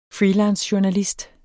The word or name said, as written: freelancejournalist